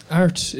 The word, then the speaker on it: Airt: Art is a residential school. It's spelled AIRT. It's the Irish word for point on a compass, direction or guide.